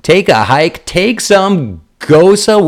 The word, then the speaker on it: gose: Better bet your bottom beer steins, my gals, the beer bracket girls are enjoying a nice gose. That's how you pronounce it, though it's spelled like rose.